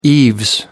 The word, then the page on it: eaves: British English